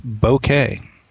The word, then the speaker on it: bokeh: Pronunciation of it, he says, is bokeh, with the W in bow being nearly silent.